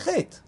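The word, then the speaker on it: chet (חטא): You would say chet. So what is the role of the aleph? What role does the aleph play in the word chet? It's a sort of a silent letter.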